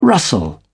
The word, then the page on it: rustle: British English